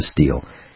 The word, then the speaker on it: Stihl: steel? And that's STIHL, pronounced steel.